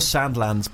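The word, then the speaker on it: Sandilands: Kyle Sanderlands. – There we go. – That's such a lot of It's actually Kyle Sandlands, but your friend said Sanderlands when you're younger and you said it wrong.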